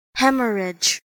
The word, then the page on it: hemorrhage: American English